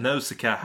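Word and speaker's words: Nausicaä: Now Shia från Vindarnas dal 84. Den har jag inte sett. – Nej, inte jag alltså. – Hur uttalar man det? Jag säger Nausicaa. Vindarnas dal.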